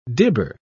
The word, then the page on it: dibber: British English